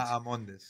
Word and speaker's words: Bahamondes: The H is silent. – Correct. – Bahamondes. – See, I said it more of a, I said it more of like a Spanish way, though, you know? So that's what I get, you know? – You injected the H, and that is where you faltered.